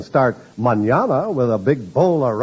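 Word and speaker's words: mañana: It's the word manana, spelled M-A-N-A-N-A, and pronounced manana. In Spanish, that means tomorrow.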